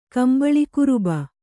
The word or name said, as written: ಕಂಬಳಿಕುರುಬ